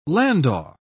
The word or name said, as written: landau